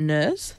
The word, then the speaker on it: NIRS: And I've written it down in my notes as like nerds, but without the D, because I just keep forgetting how to pronounce like the N-I-R-S bit.